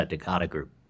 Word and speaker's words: Decada Group: Is it Decada Group? Is that how we pronounce it? – Yeah, you got it. Yeah, Decada Group.